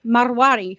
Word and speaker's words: Marwari: They're the Marwari horses.